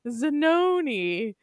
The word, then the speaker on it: Zanoni: Well, in addition to meeting Stephanie Zanuni, we meet all the team. I'm going to say it wrong every time. I don't know why I think it's Zanuni. Stephanie Zanoni.